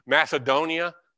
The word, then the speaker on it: Macedonia: I'm sorry, I do pronounce it with the Greek pronunciations. Macedonia, but the C should be hard.